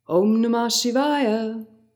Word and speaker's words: Om Namah Shivaya: Let's take it one syllable at a time. Om Namah. Om Namah Shivaya. Shivaya. Om Namah Shivaya. Om Namah Shivaya. And when it's chanted, there's an inflection, so it sounds like this.